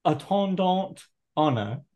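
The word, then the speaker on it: En attendant Ana: On attendant. – En attendant Ana at the end of no T. You don't pronounce the T.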